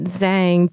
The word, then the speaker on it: Zhang: So my whole thing is that I always tell people to pronounce it Zhang because for me, I would actually rather have a separate way of pronouncing my name in English that's very clearly English than for people to try to get like brownie points for, you know, saying my name sort of right, but still sort of wrong.